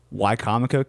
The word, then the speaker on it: Wicomico: And, you know, Wicomico County, it's not Wicomico.